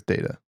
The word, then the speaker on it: Data: His name on Star Trek was Data. Data is the right way to say it.